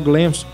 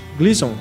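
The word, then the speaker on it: Gleeson: E esse filme tem no elenco a Maddie Hall e o Dom Hall Gleams Como que é? Gleason.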